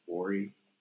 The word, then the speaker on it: Vory: Vory. How do you say his name? V-O-R-Y. Vory.